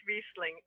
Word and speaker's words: Riesling: I still don't know if I'm saying Riesling right or Riesling. I think it's Riesling, right? – It's Riesling. R-E-E-S-E.